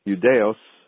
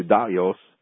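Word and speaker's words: Ioudaios: the word Jew is translated from the word Judaeos, or I guess the Greek pronunciation, Judaios, that doesn't mean the type of Jews we have today.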